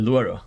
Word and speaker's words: Duero: What about the Ribera del Duomo? – Del Duero. Yeah. Good pronunciation.